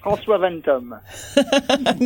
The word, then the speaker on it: François Vetterme: Did I pronounce that right, François? – Yeah, that's perfect. François Vetterme. – Not quite perfect, but it sounds a lot better when you say it.